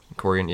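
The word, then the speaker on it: Quarian: Corian, yeah.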